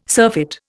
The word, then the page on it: surfeit: Indian